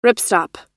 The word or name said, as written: ripstop